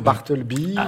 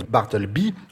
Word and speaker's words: Bartleby: Ici, l'accent est sur bar et donc bi ne peut être que bi. Et Sonia Lefebvre de Nice rappelle qu'on prononce Bartleby en raison de la présence des deux consonnes L et B avant le Y final, si vous voyez le regard d'Olivia.